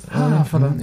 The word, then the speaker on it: Havelland: Da spricht man das V tatsächlich mit. – Havelland.